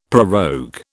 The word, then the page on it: prorogue: British English